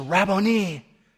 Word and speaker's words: Rabboni: She's now consumed with victory, and all she can say is, "Rabboni." Now, the reason I pronounce it that way is very specific.